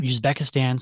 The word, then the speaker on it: Uzbekistan: Uzbekistan.